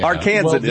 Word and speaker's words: Arkansan: Arkansan is the main term today.